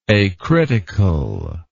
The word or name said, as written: acritical